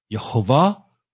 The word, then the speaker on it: Yehovah: There is no evidence that the Tetragrammaton is pronounced as Yahweh. This is something that out of confusion, people don't know. They come up with something. Yes, Yahweh. But this is not what the vowels support. Yehovah or Yahweh.